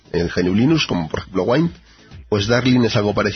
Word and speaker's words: Wine: Wine también, se escribe Wine, pero se pronuncia Wine.